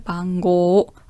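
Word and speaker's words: Bango (番号): Vorsicht, die Aussprache, Bango, also das O ist eine Verlängerung mit einem U.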